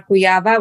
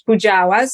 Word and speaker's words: Kujawa: And I'm also on Facebook as Dr. Joanna Kujawa, which in English pronounces Kujawa.